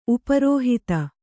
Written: ಉಪರೋಹಿತ

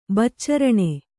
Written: ಬಚ್ಚರಣೆ

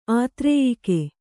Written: ಆತ್ರೇಯಿಕೆ

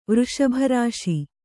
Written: ವೃಷಭರಾಶಿ

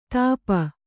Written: ಥಪ್ಪಿ

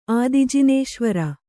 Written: ಆದಿಜಿನೇಶ್ವರ

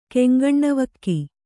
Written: ಕೆಂಗಣ್ಣವಕ್ಕಿ

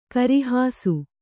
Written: ಗರ್ಹಿಸು

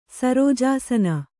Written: ಸರೋಜಾಸನ

